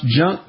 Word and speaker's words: JNK: So in the study published on this week's Journal of Science, the researchers turned off the JNK, pronounced junk genetic pathway in mice, and fed the rodents high-fat diets.